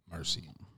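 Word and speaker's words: Mersea: Yeah, nah, it's like but it's spelled MERSSEA, Mercy. I was like, ooh.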